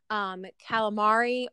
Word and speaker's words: calamari: But so that is calamari or calamad, or how do we say it? How do you say it again? – Calamar. – Calamar. So it's just like a total ending of the.